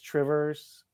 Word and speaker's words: Two Rivers: I don't know why it's Trivers. Makes no sense. – It's what? – Trivers. Two rivers.